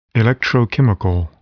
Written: electrochemical

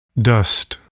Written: dust

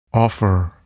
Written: offer

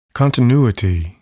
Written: continuity

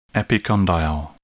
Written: epicondyle

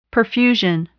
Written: perfusion

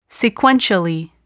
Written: sequentially

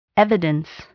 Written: evidence

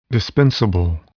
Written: dispensable

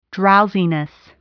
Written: drowsiness

